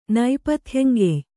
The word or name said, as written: ನೈಪಥ್ಯಂಗೆಯ್